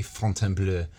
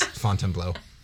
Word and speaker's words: Fontainebleau: If I want to pronounce it correctly, Fontainebleau. But I'm not that American, so it's Fontainebleau.